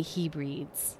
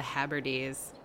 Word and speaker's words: Hebrides: Hebrides. It got more of a roll in it, more of a musicality to that pronunciation. And I'm over here, Kansas.